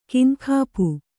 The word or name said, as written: ಕೈನೆಗಪು